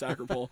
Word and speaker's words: Acropole: But like the people from Pictou County, like there's a pizza place called Acropole or Acropoli technically, but nobody from New Glasgow pronounces it Acropoli. It's Acropole.